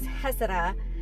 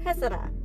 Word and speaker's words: Heceta: I kept going like heck, and then eventually I'm just like, okay, like, Google me the pronunciation, and it was Heceta, and I was like, this is so much funner to say now, Heceta.